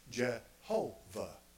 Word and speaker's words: Jehovah: Well, they put vowel points under there from Adonai. They took the pronunciation of Adonai, took the vowel points, and they put Yehovah. We say in English, Jehovah. Okay?